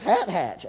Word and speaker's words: Hathach: How do you say that, Ryan, since you're so good? You don't know. It's Hat Hatch. H-A-T-H-A-C-H. How else are you going to say it?